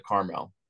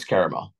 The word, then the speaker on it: caramel: Do you know anyone who pronounces it Carmel? – Sure, I know plenty of people that do. – Yeah. Do you correct them? – No, I don't care. – Yeah, it's caramel.